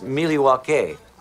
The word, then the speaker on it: Milwaukee: In fact, isn't Milwaukee an Indian name? – Yes, Pete, it is. Actually, it's pronounced Miliwake, which is Algonquin for the good land.